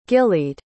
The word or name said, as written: gillied